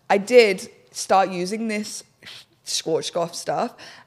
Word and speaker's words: Schwarzkopf: I used the Swarf. How do you say that? I think it's actually beard dye. That brand, Schwarzkopf. You know what? – Schwarzkopf.